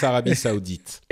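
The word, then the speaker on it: Arabie saoudite: French, it's Arabie Saudite.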